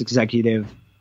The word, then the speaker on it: XECVTIVE: I'm working on a art project that people can find online that it's pronounced executive, but is spelt kind of funny, spelt XECVTIVE.